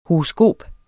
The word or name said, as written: HOROSKOPERNES